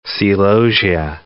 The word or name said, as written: celosia